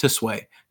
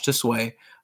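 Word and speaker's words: Tsui: I got, let's see, Josh Sway, who was, I think it's pronounced Tisway, Josh Tisway, over at, so this is like, I would say 95 to 2000s Midway, who worked on Mortal Kombat Mythologies and WWF WrestleMania.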